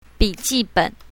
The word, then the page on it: bi3ji1ben3: Mandarin